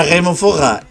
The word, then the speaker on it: Forrer: Yo lo pronuncio bien de por sí. Pero encima lo enfatizo. Es Remufog.